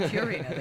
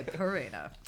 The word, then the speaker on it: Purina: and Purina, which is how they say it. Not Purina, they say Purina.